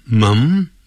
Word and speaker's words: mum: 好，一开头，my mom 就是念 mom，英国人呢会念成 m-u-m， mum mum，发呃的音，肚子呃的呃，和呃 mum， my mom is eating breakfast over there.